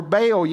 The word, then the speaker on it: Baal: And she influences him to build a temple and to begin to worship Baal, is what we would see in the scripture, or Baal, you may have heard pronounced.